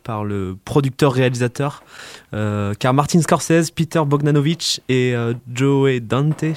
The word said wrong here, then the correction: Joe Dante: je sais pas comment on prononce ça. – Joe Dante.